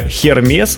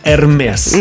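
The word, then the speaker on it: Hermès: Видим, что написано Hermes, да? А правильно говорить Hermes.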